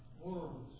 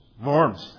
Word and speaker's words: Worms: It's spelled just like the word worms. Worms, I think, is the better pronunciation. Correct? – Worms. – Worms. That's the German pronunciation.